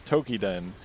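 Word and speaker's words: Toukiden: Monster Hunter series, Tweakaden, Tukaden. – Are you going to say Tokiden? – Tokiden, which was a decent enough series of two games of like Monster Hunter likes that never really found a good identity.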